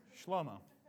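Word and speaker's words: Shlomo (שלמה): Other stuff's written by other guys with cooler names, but Solomon, or in Hebrew, his named Shlomo. Hey, Shlomo. That's how you say it.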